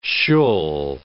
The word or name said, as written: shul